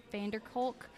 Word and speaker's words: van der Kolk: Uh, Dr. Bessel van der Kolk. Dr. Bessel, yeah, Van der Kolk is the how you say his name.